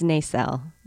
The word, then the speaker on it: Nacelle: It's Nacelle. – Nacelle. – It's a soft S, yeah.